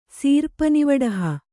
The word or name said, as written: ಸೀರ್ಪನಿವಡಹ